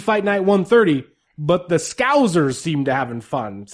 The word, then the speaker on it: Scousers: I wrote it out phonetically in my notes here, so I would say Scousers.